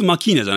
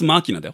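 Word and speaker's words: Machina: X マキーナじゃないよ。 X マーキーナだよ。 – マーキーナ。 – マーキーナ。でもなんかマシーンっぽいからなんかマキーナって読みたくなるよね。誰かに聞いたんだよな。 – そうなんですか。いや、英語っぽい発音で言うと、ここに、キーのところにアクセントが来るのかなと思ったけど。 – ラテン語なので、マーキナですって、なんか聞いた。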